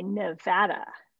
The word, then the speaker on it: Nevada: The Silver State goes by Nevada.